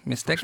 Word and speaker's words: Mixteca: en die heet Canción Mixteca, als ik het goed heb. – Mixteca. – Volgens mij spreek je dat precies zo uit.